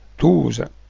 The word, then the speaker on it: Tosa: La Tosa è l'appellativo dialettale di ragazza, si pronuncia Tosa, derivato dal verbo tosare, perché nella pubertà i giovinetti maschi e femmine dovevano portare i capelli corti, cioè tosati.